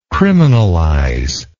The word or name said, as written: criminalization